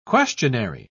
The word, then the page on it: questionary: British English